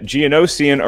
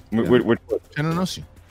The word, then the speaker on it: Geonosian: For a point, your question, what type of creature tramples Jango Fett in the Geonosian arena, damaging his jetpack during the battle? – I'm very proud of you that you said that right. – What? – Geonosian.